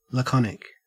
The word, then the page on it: laconic: Australian English